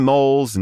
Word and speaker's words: Mol: Gretchen Mol's name is pronounced Mol, as in the Beverly Center Mall.